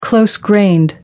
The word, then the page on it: close grained: American English